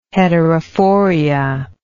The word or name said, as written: heterophoria